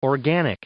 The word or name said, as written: organic